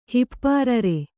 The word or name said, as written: ಹಿಪ್ಪಾರರೆ